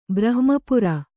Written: ಬ್ರಹ್ಮಪುರ

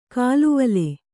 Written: ಕಾಲುವಲೆ

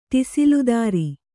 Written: ಟಿಸಿಲುದಾರಿ

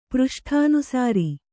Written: ಪೃಷ್ಠಾನುಸಾರಿ

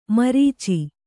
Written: ಮುರಿಚು

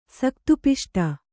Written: ಸಕ್ತುಪಿಷ್ಟ

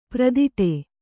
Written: ಪರದಾಡು

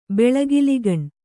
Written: ಬೆಳಗಿಲಿಗಣ್

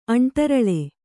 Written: ಅಂಟರಳೆ

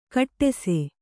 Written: ಖೋಡಾಸು

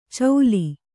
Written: ಚೌಲಿ

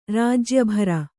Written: ರಾಜ್ಯಭಾರ

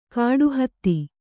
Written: ಕಾಡುಹತ್ತಿ

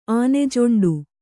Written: ಆನೆಜೊಂಡು

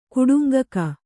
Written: ಕುಟುಂಗಕ

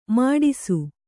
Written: ಮಡಿಸು

